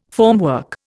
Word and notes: formwork: Indian

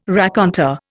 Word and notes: raconteur: Indian

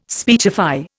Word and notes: speechify: Indian

